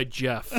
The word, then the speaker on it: Geoff: Yeah, I think it goes by Jeff, actually. Yeah, the silent yeah. R. – It's yeah. – just one of the funny spellings. It's yeah. like G-E-O-F-F. It's pronounced Groff.